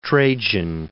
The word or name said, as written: Trajan